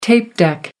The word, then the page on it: tape deck: American English